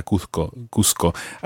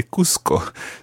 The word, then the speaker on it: Cuzco: I said Cusco there because it's spelled C-U-Z-C-O and I speak Spanish with a Spanish accent. But Cusco is a Peruvian town and it's much more normal in Cusco to say Cusco. So that's why there's a little bit of confusion there. But Cusco in Spanish pronunciation, but in Peru it would be called Cusco.